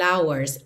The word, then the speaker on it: hours: E hours é uma das pouquíssimas palavras em inglês que o H é mudo.